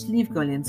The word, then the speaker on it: Slieve Gullion: So it's Slieve Gullion. So Slieve Gullion in English is spelled S-L-I-E-V-E, Slieve Gullion, G-U-L-L-I-O-N, and it means nothing, absolutely nothing.